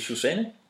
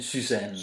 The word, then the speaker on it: Susanne: Han lærer Benny og Kjeld, at U på fransk udtales som Y. – Ja. – Så hun hedder ikke Susanne, men Susanne.